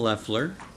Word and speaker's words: Leffler: I'm on their legislative committee, and my name is Al Leffler. – And I also, how do you spell that? – L-E-F-F-L-E-R.